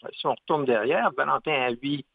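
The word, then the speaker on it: Haüy: Oui, l'association Valentin Huy, Huy c'est H-A-U-Y, alors vaut mieux le prononcer que le lire.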